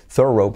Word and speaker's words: thorough: es como un zorro, un nativo que dijera zorro, oh, un zorro, qué bonito, a thorough, un bonito thorough, ok, que viene al animal y dijeran thorough, pues así se pronuncia, ¿vale?